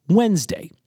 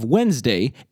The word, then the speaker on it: Wednesday: And second, the D in Wodnesdag, which has fallen silent in Wednesday, is still pronounced.